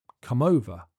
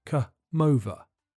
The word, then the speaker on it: come over: Come over. The M sound at the end of come links to the beginning of over, so it sounds more like come over.